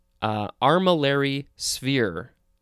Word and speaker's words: armillary sphere: All right, next we have armillary sphere. Yeah, that's how it's pronounced.